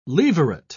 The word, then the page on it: levirate: British English